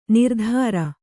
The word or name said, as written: ನಿರುತ್ತರೆ